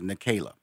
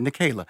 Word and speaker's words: Nicaila: Okay, I did say it correctly, Nicaila. Okay, Nicaila.